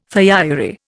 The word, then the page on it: thiourea: Indian